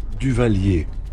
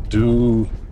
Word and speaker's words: Duvallier: And with us is the curator of that museum, Jacques how do you pronounce your last name? – Duvalier. Duvalier. – How do you spell that? – D-U-V-A-L-L-I-E-R.